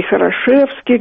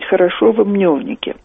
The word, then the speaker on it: Хорошево-Мневники: Кстати, часто неправильно произносят это название. И Хорошевские говорят там, и Мневники говорят. А на самом деле надо говорить Хорошево-Мневники.